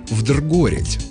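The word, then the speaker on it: вдругорядь: Это сокращение от старинного наречия «вдргорить». Да, именно так, с неочевидным ударением на «о».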